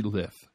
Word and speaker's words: Lyth: It always seems like when I say that it's wrong, but that's it, Jeffrey Lyth. Yep, that's it. I said it right.